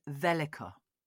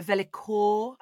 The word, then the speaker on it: vellichor: Can you spell it for me? – Well, V-E-L-L-I-C-H-O-R. So velikor, I suppose you could go, but I've done a bit of research, and I'm reliably informed that you pronounce it velika.